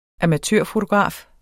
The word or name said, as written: amatørfotograf